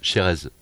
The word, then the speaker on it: Chérez: J'en profite pour dire aux auditrices et aux auditeurs que je m'appelle Chérez. Donc C-H-E-R-E-Z. Je tiens à le préciser parce que... – Chérez. Eh oui, on est dans les Landes ici, on prononce tout.